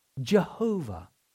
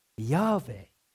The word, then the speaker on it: YHWH: So it stands for a Hebrew four-letter word that is spelled YHWH. YHWH. In English, we pronounce it Jehovah or Yahweh.